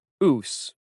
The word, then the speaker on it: Ouse: Ouse, TJ corrected, breaking into a grin. It rhymes with moose.